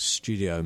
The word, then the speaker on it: Stiwdio: I mean, one of the things about the residency, and I should mention the name of the residency, which is Studio Maylor. It's spelled Stiwdio, which is the Welsh way of saying studio.